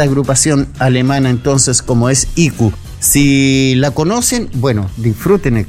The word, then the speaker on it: IQ: No, conocíamos a esta banda que vamos a presentar ahora, que es IQ. IQ. Y se escribe así: I latina C U. Así de simple.